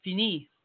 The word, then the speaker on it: Fenix: And this is F-E-N-I-X LD20. – Fini.